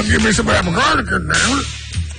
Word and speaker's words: Picaridin: We're gonna get the official pronunciation here on Picardigan. Picardigan. – Picaridin is modeled after the piperine molecule. – You didn't even have it said it wrong.